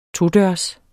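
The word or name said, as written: TODØRS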